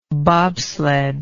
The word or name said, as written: bobsledder